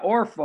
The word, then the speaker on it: Orpah: What, somebody, how do you pronounce her name? Orpha? Yeah, Orpha, O-R-P-A-H, Orpha.